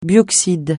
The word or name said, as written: bioxyde